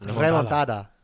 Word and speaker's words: remontada: Say it properly. Come on. – What, remontada. – Sí, gracias.